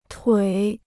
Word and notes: 腿: tuǐ

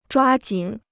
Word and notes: 抓紧: zhuā jǐn

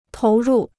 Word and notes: 投入: tóu rù

